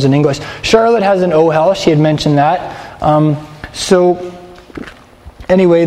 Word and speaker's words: ohel: In Hebrew, a tent is an ohel. O-H-E-L. Everybody say ohel. – Ohel. – Yes. Emphasize the last syllable in that word. Alright. Don't emphasize it.